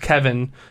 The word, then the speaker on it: Cavan: It's C-A-V-A-N. I don't know. – Cavani? – No, because I'm pretty sure it's pronounced Kevin, but it's Cavan, and then it's Biggio.